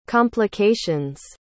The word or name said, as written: complications